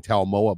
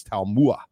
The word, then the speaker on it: Taimua: I, I, I had been saying Talmoa, but it's Taimua.